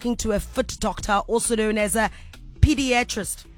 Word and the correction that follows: podiatrist: Did I say that right? – Podiatrist. Foot doctor.